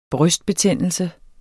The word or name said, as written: brystbetændelse